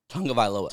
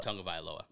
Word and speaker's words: Tagovailoa: With the quarterbacks flying off the board, does he have the balls to pass on Tua Tagovailoa? I didn't say that right. – Tagovailoa. – Tagovailoa.